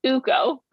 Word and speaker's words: Luukkonen: Jukka-Pekka Luukkonen. Did I say that right? – Not bad. It's Uko, but otherwise you were pretty good.